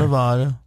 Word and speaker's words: Nevada: Or is it Nevada? How do they pronounce it out there? – Nevada.